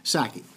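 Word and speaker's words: Psaki: So no, the P is silent. The P is silent. Sackey.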